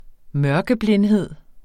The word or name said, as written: mørkeblindhed